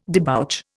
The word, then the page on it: debouch: Indian